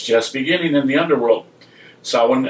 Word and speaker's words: Samhain: Religious scholars agree that the word S-A-M-H-A-I-N is pronounced Samhain, comes from the Gaelic Samhain, but they're divided on whether it means the end or beginning of summer.